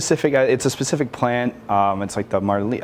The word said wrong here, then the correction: Moringa: I'm going to butcher it if I say it. Moringa. Yeah, Moringa. There you go.